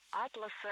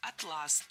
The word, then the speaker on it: атлас: Перед нами классические омографы. То есть слова, которые одинаково пишутся, но по-разному произносятся. Вот и атлас, и атлас пишутся одинаково, но слова-то это разные, с разным значением. И различить их можно только в устной речи с помощью ударения.